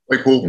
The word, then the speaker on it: Claypool: Uh, I think it's Claypool. Is how you say his name? Claypool. – Mm-hmm. Yeah. Mm-hmm.